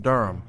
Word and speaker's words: DURM: Let's get a taste of your song D-U-R-M, which I assume is pronounced Durham. – Yes. – What does D-U-R-M stand for? – Do you remember me?